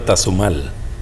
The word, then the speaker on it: Tazumal: Radio Tazumal.